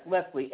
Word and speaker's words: Leslie: Yes, Leslie, L-E-S-L-I-E.